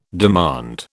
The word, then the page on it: demand: British English